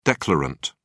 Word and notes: declarant: British English

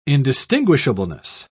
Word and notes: indistinguishableness: British English

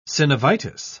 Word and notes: synovitis: British English

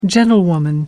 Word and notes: gentlewoman: American English

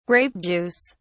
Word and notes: grape juice: British English

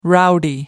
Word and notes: rowdy: American English

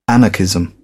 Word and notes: anarchism: British English

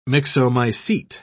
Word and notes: myxomycete: British English

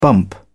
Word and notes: bump: British English

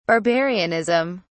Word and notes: barbarianism: British English

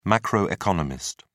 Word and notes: macroeconomist: British English

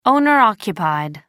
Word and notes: owner occupied: American English

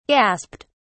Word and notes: gasped: British English

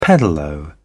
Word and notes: pedalo: British English

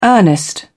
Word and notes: earnest: British English

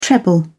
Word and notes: treble: British English

